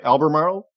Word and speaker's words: Albemarle: Albemarle? – Yes.